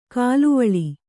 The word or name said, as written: ಕಲುವಳೆ